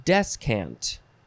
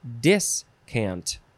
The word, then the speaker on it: descant: Descant. Yeah, descant. D-E-S-C-A-N-T. First form. You could also spell it and pronounce it discant.